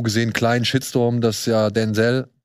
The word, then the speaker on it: Denzel: Darf jetzt wieder Denzel sagen. Weil es ist so, das ist sein Name. Er darf, er wird so ausgesprochen.